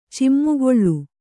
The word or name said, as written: ಚಿಮ್ಮುಗೊಳ್ಳು